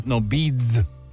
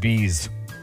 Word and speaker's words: Beads: Beads. – So yeah. – No, you said bees. – I just didn't pronounce my D. – Always good to pronounce that D.